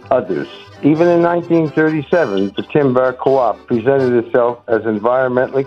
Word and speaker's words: Tymbark: Timbark is spelled T-Y-M-B-A-R-K.